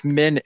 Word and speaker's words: Min: So also, Moraine asked the innkeeper if Min is still there. M-I-N is pronounced Min.